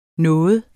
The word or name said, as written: nogen